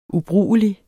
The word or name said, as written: UBRUGELIGT